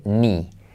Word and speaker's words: knee: Y prueba de ello estas palabras, la primera de ellas, rodilla, que tiene, se escribe con K, pero fíjate que se dice ni, ni, ni temenés ni.